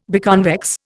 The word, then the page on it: biconvex: Indian